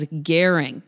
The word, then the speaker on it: Goehring: I now know to pronounce her name as Goehring, just like caring.